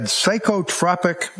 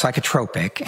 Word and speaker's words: psychotropic: She also had psychotropic medication with adverse reaction. Your words to this jury yesterday. – Yes. – What is psychotropic medication with adverse reaction? What does that mean? – It's pronounced psychotropic.